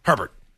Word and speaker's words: Herbert: Herbert.